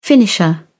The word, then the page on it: finisher: British English